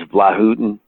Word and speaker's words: Vlautin: and he set me straight on how to pronounce his last name. – it's just it's a difficult name. It's uh, Yugoslavian or former Yugos. It's Croatian, and okay. uh, um, and I think it was Vlahutin, oh.